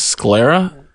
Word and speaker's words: sclera: The tattoo was meant to have tinted her, how do you say this, sclera. S-C-L-E-R-A.